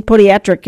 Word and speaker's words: podiatric: The Michigan Podiatric Medical Association, podiatric is the better pronunciation, Medical Association reminds you to, well, take care of those feet, and one in the mood to do that, Dr. Jody Sengstad.